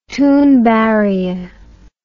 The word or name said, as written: thunberg